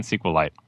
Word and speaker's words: SQLite: says how to pronounce it. So anyway, I'm saying SQLite. You're gonna have to deal with it.